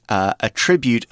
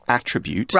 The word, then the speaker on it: attribute: Now, notice that when we're using attribute as a verb, we're pronouncing it differently to the noun attribute.